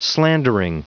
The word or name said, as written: slandering